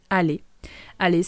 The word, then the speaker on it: aller: Aller, c'est escribe A-L-L-E-R en infinitivo. Y como todos los verbos que terminan en E-R en francés, se pronuncia E al final. Aller, aller. La R final es totalmente muda.